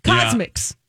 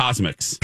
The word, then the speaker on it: CosMc's: I don't not the emphasis on the mix. CosMc's. – Yeah. CosMc's. – I think we just blend it together. – CosMc's.